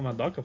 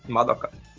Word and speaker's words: Madoka: É, é que todas têm acento, né? Geralmente. Todas as sílabas têm acento. – É. Aí tipo, é Madoka. Todas, todas, todas ficam entonadas, né? Madoka.